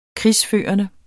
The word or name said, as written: krigsførende